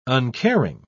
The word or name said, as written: uncaring